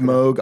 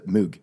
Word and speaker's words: Moog: It's pronounced Moog, often anglicized to Moog.